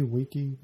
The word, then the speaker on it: Wiki: And am I saying that right? Wiki? – Wiki, kind of like Wikipedia.